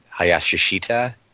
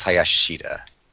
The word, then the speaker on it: Hayashishita: I think he was saying it Hayashishita, I think. – I was just going phonetically, but it's very long. – I think it's Hayashishita.